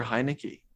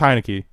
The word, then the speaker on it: Heinicke: I know how to say his name, but you say it wrong. You've said it wrong like 10 different ways throughout the duration of this podcast, and now I'm confused. I think it's Heinicke.